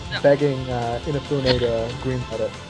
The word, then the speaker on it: Inafune: I thought it was Unifane. I have heard it pronounced Unifane on the Game Makers thing. – How do you pronounce it? – Inafune.